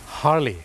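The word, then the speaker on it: Harley: นะครับและไหนๆก็ไหนๆนะครับอย่างเช่นรถมอเตอร์ไซค์ที่คนไทยทุกคนเนี่ยรู้จักคือรถฮาร์เลย์ถูกไหมครับคนไทยออกเสียงว่าฮาร์เลย์แต่เจ้าของภาษานะครับไม่ได้ออกเสียงว่าฮาร์เลย์ครับเขาออกเสียงว่าฮาร์ลีฮาร์ลี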